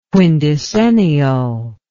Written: quindecennial

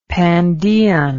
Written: pandean

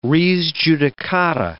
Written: res judicata

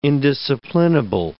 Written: indisciplinable

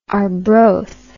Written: arbroath

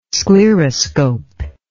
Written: scleroscope

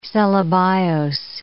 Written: cellobiose

